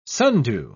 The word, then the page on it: sundew: British English